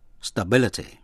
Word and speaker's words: stability: Cũng nhận xét về cách phát âm tĩnh tự stable. Thì âm A đọc là A, stable, là vững. Còn danh tự stability, thì âm A đọc là A. Quý vị nghe lại, stable, stability.